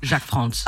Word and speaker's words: Jacques Franz: T'as fait une vidéo et donc je pense que c'est avec Jacques Franz, je sais pas si je prononce bien. – Jacques Franz.